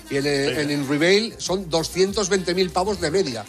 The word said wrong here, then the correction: Unrivaled: Unrivaled. Vía patrocinador. – O sea, pronúncialo bien, que estamos... a ver, Unrivaled. No es una palabra fácil, ¿eh?